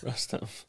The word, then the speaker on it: Rustem: It looks as if Rustav... I gave him a different name. Rustem. God damn it. How do you say it? – Rustem. – Oh, okay. Rustem.